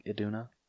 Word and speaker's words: Iduna: The sisters discover the sculptures are images from their father's past and that their mother, Queen Iduna, I think I said that right. Does that look like Iduna? Yes. Okay, thank you.